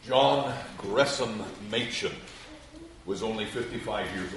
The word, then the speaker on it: Gresham: Gresham, that is how his name is pronounced. He clarifies that. It's spelled Gresham, but it's pronounced as Gresham.